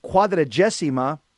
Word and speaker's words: quadragesima: In Latin, it, uh, it, it's pronounced, the 40 days is pronounced quadragesima. That's the Latin way of saying it.